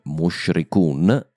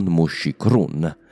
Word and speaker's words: Mushrikun: Inoltre, Mushrikun e non Mushikrun.